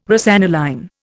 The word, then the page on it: rosaniline: Indian